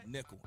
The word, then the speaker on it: Nickel: This first song is my theme song, Boog Nickel Butt. Spell it right, Nickel. N-I-C-K-E-L.